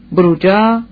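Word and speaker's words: Bruggia: بروجا، تلفظ ایتالیایی بروژ، بروژز، شهر معروف بلژیک که در زمان دانته از امروز به دریا نزدیکتر بود.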